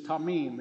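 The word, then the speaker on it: tamim (תמים): It's tamim. It's T-A-M. I-M or T-A, it's pronounced like T-A-M-E-E-M.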